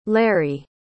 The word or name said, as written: larry